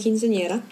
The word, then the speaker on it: quinceañera: It was my quinceañera. That's how you're supposed to pronounce it, actually.